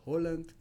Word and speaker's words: Haaland: Eh, ah, otra, otra aula grande, eh, la pronunciación correcta de los nombres noruegos con doble A, las doble A se pronuncian como O. Entonces el correcto sería Erling Braut Holland y eh, Martin Odegaard.